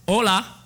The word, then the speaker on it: hola: Hola. Go ahead, say it. Hola. Hola, no, it's not hola, because it's, even though it's H-O-L-A, the H is silent, because again, if you saw it, hola, hola, hola, no, it's not hola, which some of you may say, hallo, hala.